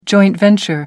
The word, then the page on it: joint venture: American English